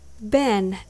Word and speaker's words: been: When you say been, don't say been. Ben is pronounced like B I N, ben, a container. Can you hear the difference between ben and bean?